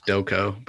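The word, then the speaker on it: Stokoe: Batman Annual Number 5 by James Tynion IV, with art and colors by James Stoke. I think that's how you pronounce the last name. – I think it's Stoko, but I'm not Stoko. 100%. – Okay, yeah, that's probably, that makes more sense.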